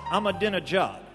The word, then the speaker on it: Ahmadinejad: Wait, I'm being told it's pronounced Ahmadinejad. Now I'm being told there's nothing in my ear.